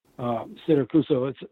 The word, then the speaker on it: Siracusa: Siracusa, it's spelled exactly like the town in Sicily.